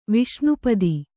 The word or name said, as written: ವಿಷ್ಣುಪದ